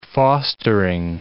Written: fostering

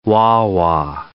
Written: wah-wah

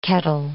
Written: kettle

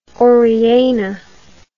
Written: Oriana

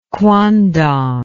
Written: quandong

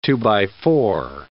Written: two-by-four

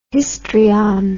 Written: histrion

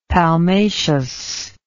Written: palmaceous

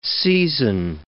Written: seizin

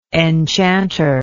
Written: enchanter

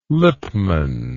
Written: lipmann

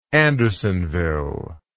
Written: Andersonville

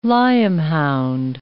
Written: lyam-hound

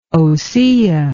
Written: ossia

